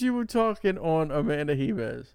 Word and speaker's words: Ribas: I think everybody's calling Ribas. Ribas. – Ribas. – Because, you know, Brazilian, yeah, Portuguese.